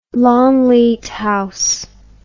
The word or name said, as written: Longleat House